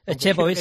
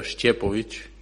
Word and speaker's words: Šćepović: Por eso llevo en la camiseta Stefan, que es lo más fácil pronunciarlo, pero bueno, el apellido se pronuncia Šćepović, que es bueno, es difícil de pronunciarlo.